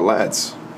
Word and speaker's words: LHADS: The cool thing is that Let's Have a Drink Sometime actually kind of spells out lads. – Yeah. – And the H is silent.